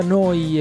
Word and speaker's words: NEU: Noi Radio si scrive Neu, si pronuncia Noi e vuol dire nuova emittente urbana in diretta 24 ore su 24, 7 giorni su 7 da Bologna.